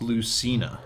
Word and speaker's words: Lucina: And apparently, this just in, it is pronounced Lucina. – Oh, Lucina. – Okay. – Reading. – Lucina. – Which is dumb. – You like the CH sound? – I think, yeah.